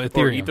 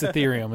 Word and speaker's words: Ethereum: You could give yourself as much urethium as you wanted. – Oh, Ethereum. – Or ether, whatever it is. Ethereum. I don't know how to say the word. – It's Ethereum. It's not a urinary tract.